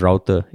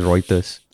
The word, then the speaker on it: Reuters: And amongst all the very well thought out comments and all, this comment was basically small matter, but did pronounce Reuters as router? Isn't it pronounced as Reuters? – Yeah. – Then I was thinking like, fuck, did I say Reuters or Routers? – You said Routers, I think.